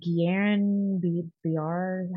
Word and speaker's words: Guillain-Barré: I think, is it Guillain-Barré? How do you say that? – That sounds right.